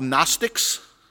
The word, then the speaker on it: Gnostics: Gnostics, spelled with a G. The G is silent.